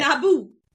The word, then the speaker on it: Naboo: It rhymes with taboo. – I got it. – Naboo.